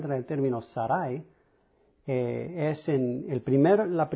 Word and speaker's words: Sarai: Y lo que hice, escudriñé el término Sarai, es número 82, 97, número Strong's, se pronuncia Sarai, en el hebreo.